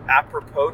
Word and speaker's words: apropos: A prop's nothing. – I think that word is apropos. – Apropos nothing? – Yes. It does have the S in everything, but it's pronounced apropos.